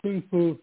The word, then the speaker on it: sifu: The kung fu had sifu, or I think it's called, it's spelled S-I-F-U, but they want to say the, kind of like say the X, sifu.